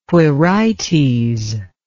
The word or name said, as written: quirites